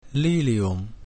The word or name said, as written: lilium